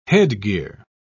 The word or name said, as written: headgear